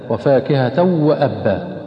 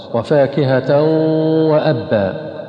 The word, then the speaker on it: وفاكهة وأبا: فبعض الناس ينطقها خطأ هكذا وفاكهة وأبا، وفاكهة وأبا هذا إدغام من غير غنة، النطق الصحيح هكذا إن شاء الله، وفاكهة وأبا.